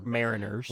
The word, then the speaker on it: Mariners: So now you got Houston Astros facing the Seattle fucking, I always say this wrong, Mariners. – Well, I always want to say Miners, but like they're Mariners.